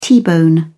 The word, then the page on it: t-bone steak: British English